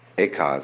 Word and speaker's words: Eka: Eka is Japanese pronunciation, like Zen is the Japanese pronunciation of the Chinese Chan.